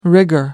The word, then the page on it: rigor: British English